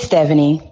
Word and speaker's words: Stephanie: It's Stephanie.